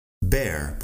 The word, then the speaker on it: bear: Pones la B al principio de la palabra air, de aire, y tienes bear. Da igual que no se escriba de la misma forma. La cuestión es que la pronunciación en inglés es así.